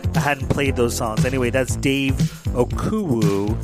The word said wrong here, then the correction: Okumu: Am I saying his name right? Oh my goodness. Dave Okumu. O-K-U-M-U.